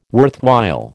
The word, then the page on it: worthwhile: American English